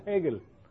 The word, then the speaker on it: Hegel: Este es el hombre, se llama, y se pronuncia así porque es alemán, se pone con H, pero la H no es silenciosa, es uno de los únicos casos en el español que la H no es silenciosa. Aquí se pronuncia Hegel en español, suavemente.